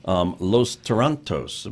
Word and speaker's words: Los Tarantos: And so tonight, as a matter of fact, here at San Juan College, part of the Cinematech series of foreign films, Los Tarantos. Am I pronouncing that correctly? – Yes.